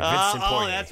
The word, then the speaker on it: Vincent Poirier: You get Poirier's name right, but you deliberately say Bertan's first name wrong? – Well, it's like Christian Fourier, so it's very easy for me.